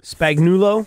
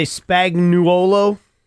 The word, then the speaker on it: Spagnuolo: I've heard it said Spagnuolo. – 45 different ways. – Should we say Spagnuolo? – Spagnuolo.